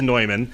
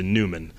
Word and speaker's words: Neumann: People mispronounce it. – It is actually Neumann. – He was from German origin and the proper pronunciation is Bohemia. – You're absolutely right. The proper pronunciation is Neumann, but we here in the Delaware Valley area and most of the United States just Americanized it to Neumann. But St. John Neumann is correct, and Bishop is nothing if not someone who enjoys pronouncing things correctly.